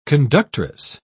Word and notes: conductress: British English

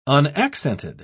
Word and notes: unaccented: British English

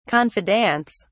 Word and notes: confidants: British English

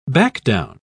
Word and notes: backdown: British English

